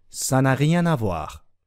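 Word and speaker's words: ça n'a rien à voir: サナリアナボアーって聞いたことや言われたことないですかサナリアナボアーと書きます。発音も確認しておきましょう。サナリアナボアー。さ n'a rien à voir.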